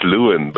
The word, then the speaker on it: Lewin: Like Lewin in, I hope I'm pronouncing that correct. Lewin Balhar, good morning. – Yes. Morning, Lester. It's Lewin.